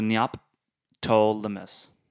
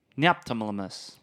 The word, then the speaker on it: Neoptolemus: N-E-O-P, so Neoptolemus. Neoptolemus.